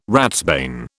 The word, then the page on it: ratsbane: British English